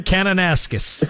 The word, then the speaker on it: Kananaskis: Kananaskis.